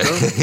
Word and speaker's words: Chateau: Where Chateau, you said that wrong. Chateau Chalet. Chateau? You're supposed to say it like that.